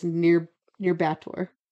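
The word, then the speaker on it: Nyírbátor: I had to use the pronunciation thing. It's near Bator.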